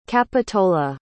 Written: Capitola

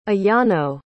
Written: ayano

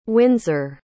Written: Windsor

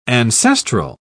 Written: ancestral

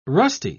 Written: rusty

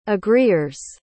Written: agreers

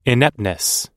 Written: ineptness

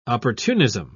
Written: opportunism

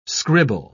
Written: scribble

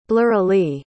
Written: blurrily

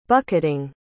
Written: bucketing

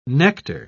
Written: nectar

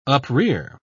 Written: uprear

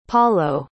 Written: palo

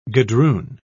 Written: gadroon

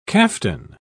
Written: caftan

